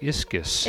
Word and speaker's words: ischys: and then strength is the word ischesis, or how do you say that? – Yeah, I would say ischus. – Ischus. – I-S-C-H-Y-S.